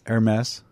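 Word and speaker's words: Hermès: How do you pronounce, like, it looks like herpes? – Hermes.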